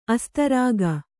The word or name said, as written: ಅಸ್ತರಾಗ